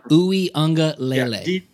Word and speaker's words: Uiagalelei: DJ Uiagalelei.